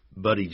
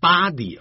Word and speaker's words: buddy: Hey buddy， buddy 就是老兄， B-U-D-D-Y，可不要念成 body 啊， body 代表尸体或身体，那是 body。